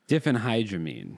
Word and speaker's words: diphenhydramine: but it is DHP. – Diphenhydramine. – Different hydramines. – Different by the abuse of different hydramines. – It's different. – No, it's diphenhydramine.